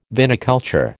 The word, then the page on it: viniculture: American English